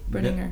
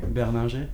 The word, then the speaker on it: Berninger: How would you say that in French? Would it be Berninger? – Berninger. – Mm-hmm. – It would sound like that.